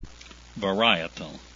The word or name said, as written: varietal